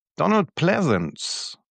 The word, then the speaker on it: Pleasence: Donald Pleasence, ich glaube, so spricht man ihn aus, oder? – Ich glaube schon, ja.